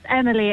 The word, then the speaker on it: Emily: M-I-L-Y? That's right? – You are. It's Emily. It's not the French. Emily.